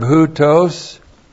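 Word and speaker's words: houtos (οὕτως): The adverb houtos H-O-U-T long O-S, houtos is translated so, it denotes degree, so, and then agapao, the verb of virtue, love.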